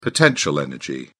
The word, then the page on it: potential energy: British English